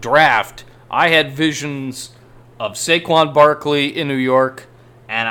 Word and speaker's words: Saquon: And can Saquon Barkley...